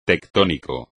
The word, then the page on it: tectónico: espagnol